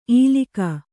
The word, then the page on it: ಈಲಿಕ: īlika